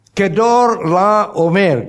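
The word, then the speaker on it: Chedorlaomer: And then another one, the most strongest among them is Kedor La Omer. I know most people can't even pronounce his name. Say with me. Kedor La Omer. Can you say it? You just spoke Hebrew.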